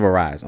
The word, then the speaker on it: Arise: Tales of Azir, I think that's how you say it. Yeah. Tales of Arise, I'm sorry.